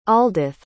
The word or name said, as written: aldyth